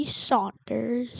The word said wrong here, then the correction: Sanders: Sanders, whatever.